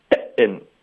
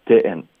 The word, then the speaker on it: didn't: 你的喉音停顿到位，OK，是对的，didn't，可是你特别重的在报 didn't，我们不要这样发音，它的力度是一致的，didn't。